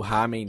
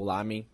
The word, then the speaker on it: ramen: É, em Ipanema e o, o ramen deles ou lamen, para quem, enfim, enfim, tanto faz a, a pronúncia, é muito bom.